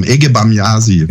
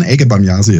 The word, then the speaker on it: Ege Bamyasi: Ja, ich weiß nicht, ob ich es richtig ausspreche. Ege Bamyasi. – Ja, genau, was heißt das denn? – Das ist, die Band heißt der Can.